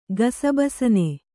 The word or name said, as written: ಗಸಬಸನೆ